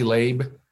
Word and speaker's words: Labe: Yeah, and I want to make sure, am I pronouncing your last name correctly, Labe? – Great.